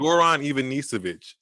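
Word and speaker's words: Goran Ivanišević: Oh, Goran Ivanisevic.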